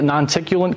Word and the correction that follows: noctilucent: Non-ticulant cloud? Noctilucent. Yeah, yeah. I've mispronounced it like 1,000 times.